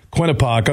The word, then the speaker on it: Quinnipiac: They'll take on Quinnipiac.